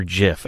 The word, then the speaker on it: GIF: It's GIF, right? Yeah. I hear some people say Jif. I'm like, what the hell are you talking? – It's what? – Not peanut butter. – Yeah, right? It's a G.